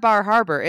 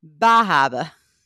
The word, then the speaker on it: Bar Harbor: Oh, my husband says it's not Bar Harbor. It's Bahaba. Okay. That's how you pronounce it.